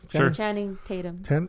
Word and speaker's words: Channing Tatum: Is that how you pronounce his name? – Channing Tatum.